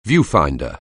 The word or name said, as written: viewfinder